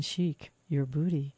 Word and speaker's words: Sheik Yerbouti: And the title of the album is spelled Sheik, S-H-E-I-K, Y-E-R-B-O-U-T-I, Sheik, as in Sheik, your booty, Frank Zappa.